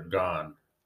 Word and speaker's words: Gane: of Gane right here. Or gone. I always say that name wrong.